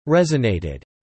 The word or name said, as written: resonated